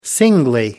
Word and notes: singly: American English

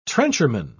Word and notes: trencherman: British English

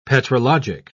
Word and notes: petrologic: British English

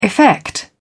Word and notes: effect: British English

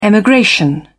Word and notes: emigration: British English

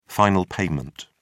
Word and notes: final payment: British English

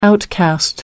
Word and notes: outcast: American English

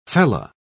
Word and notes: fellah: British English

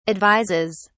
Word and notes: advises: British English

